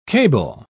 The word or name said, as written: cable